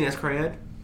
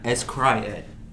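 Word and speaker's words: s-CRY-ed: You ever seen Escryed? – What? – Escryed. – I don't think you're saying that right. – It legit the letter S cry dash ed.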